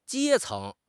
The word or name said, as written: 阶层